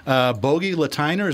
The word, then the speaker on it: Bogi Lateiner: Our first guest is, hopefully I'm pronouncing this right, Bogi Lateiner. Is that correct? – It. Very good.